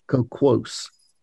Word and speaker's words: coquos: So, yes, yeah, so it's coquos, C-O-Q-U-O-S.